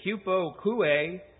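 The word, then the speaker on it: hupokoue (ὑπακοή): The first word that I would like to look at tonight is hupokoue.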